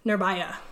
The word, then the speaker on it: Nirbhaya: Or, I'm sorry, it's Nirbhaya. I'm so sorry for mispronouncing that.